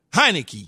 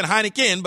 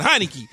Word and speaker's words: Heinicke: And Tyler, I think I'm going to say his name right, Taylor Heinicke. – Correct. – Yes. Yes. I almost said Heineken, but Heinicke as a starter going into next season.